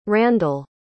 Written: Randall

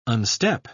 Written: unstep

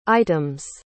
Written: items

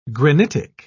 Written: granitic

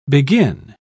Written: begin

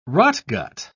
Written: rotgut

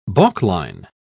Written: balkline

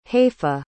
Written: hayfa

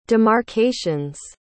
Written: demarcations

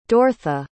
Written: dortha

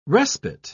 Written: respite

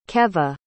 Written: keve

